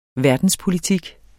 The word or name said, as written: verdenspolitik